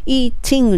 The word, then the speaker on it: eating: eating の t のところももっと砕けて eating ここも L の発音に近く